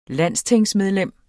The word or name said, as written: landstingsmedlem